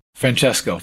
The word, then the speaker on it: Francesco: Now there was a there's a documentary, a newly released documentary, and uh, it's uh, called Francisco. Am I saying that right? Francesco. Francesco. See, mm-hmm.